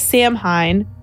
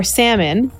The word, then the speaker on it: Samhain: Our popular holiday, Halloween, is originally called Samhain, spelled S-A-M-H-A-I-N, very commonly mispronounced as Samhain or Salmon. Yes. – Ah. – It's Samhain.